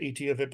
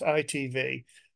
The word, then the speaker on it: ITV: They pronounce it ETV, but it's ITV.